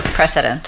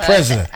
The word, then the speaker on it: precedence: Were better and took precedence. – Took precedence. Precedence. Not president.